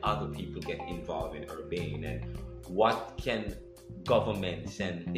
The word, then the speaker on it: Urbane: But as you think about the future of Urbane, or how do you pronounce it? – Urbane. – I'm saying it right? – Yes.